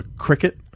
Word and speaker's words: Cricut: It's called a Cricut, but it's spelled C-R-I-C-U-T.